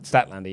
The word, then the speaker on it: Statlander: Statlander, yes.